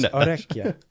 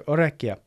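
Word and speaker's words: orecchio: I think it's orecchia. I think the two C's. – Double consonant in Italian usually means pronounced. It's like spaghetti. Like it should be very pronounced.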